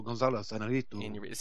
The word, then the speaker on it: Iñárritu: Alejandro Iñárritu. – Alejandro González Iñárritu. – See, you can roll the R's. I should just have you say all these for me. Yeah. Iñárritu for The Revenant.